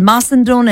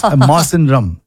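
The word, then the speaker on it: Mawsynram: and I'm pretty sure we are not pronouncing it right. – You are not pronouncing it right. – Oh, do you think so? – Because I went to YouTube and said how to pronounce. – Oh. – It's Masandrum.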